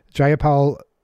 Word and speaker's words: Jayapal: Jayapal tweeted, am I pronouncing that right? – Yes.